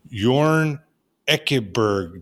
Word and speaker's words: Bjorn Ekeberg: Today we have with us Bjorn Ekeberg.